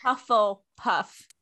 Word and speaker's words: Hufflepuff: Hubblepuff? Am I saying that right? Hubble? What? Girl, I don't know what it is. I don't even know how to say it. I can't pronounce it right. – Hufflepuff. – Hufflepuff. – There you go.